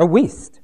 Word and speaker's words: Wuest: W-U-E-S-T, West or Worst or some, anyone know how that's pronounced? West. A Weast. Weast. I have not thought about that.